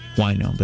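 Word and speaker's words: huayno: It's spelled, by the way, it's not wino like somebody who's drunk on wine. It's H-U-A-Y-N-O, wino, but in English it sounds like wino, unfortunately.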